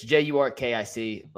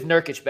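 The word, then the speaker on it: Nurkić: The Blazers also got DeAndre Ayton from the Phoenix Suns in exchange for Nursif Jurcic. I never say his name right. It's Jurkic. Let me just pronounce it properly. They got Yusuf Nurkic back. Nurkic, excuse me.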